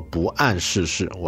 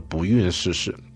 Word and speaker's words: 不谙世事: 当然也有朋友挑错啊，我在里面读字读错了，有一个字叫做不谙世事，我读成了这个不孕世事啊。